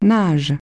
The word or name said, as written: nagent